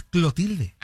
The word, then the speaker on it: Clotilde: Doña Cleotilde. – Era Clotilde, ¿no? Acá es Clotilde. – Ah, pues tal vez era Clotilde y nosotros lo pronunciábamos mal.